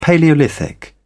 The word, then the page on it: palaeolithic: British English